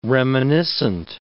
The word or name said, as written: reminiscent